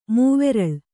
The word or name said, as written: ಮೂವೆರಲು